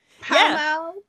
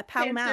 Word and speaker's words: Pall Mall: But Amy doesn't need a sub for, and neither does Pall Mall. Did I say that right? – Pall Mall. – Yeah, Pall Mall.